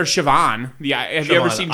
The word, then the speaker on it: Siobhan: S I O B H A N. – There's a fucking B in it, and it's pronounced Siobhan.